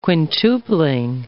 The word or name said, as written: quintupling